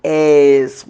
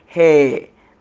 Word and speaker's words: heir: So the heirs is not A-I-R, but H-E-I-R. So some people say hair, but it is heir. As like, that's how it's supposed to be pronounced, heir, the heir of the throne.